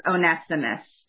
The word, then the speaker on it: Onesimus: I will also be saying the other major character's name as Onesimus. And I have heard many other ways of pronouncing his name as well.